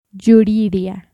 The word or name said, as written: Yuriria